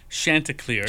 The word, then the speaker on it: Chanticleer: Now, correct me if I'm saying it wrong. Chanticleer? – It's Chanticleer.